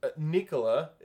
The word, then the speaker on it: Nikola: It is deliberate. It is the way that you pronounce Nikola in Serbian. That's how it's said.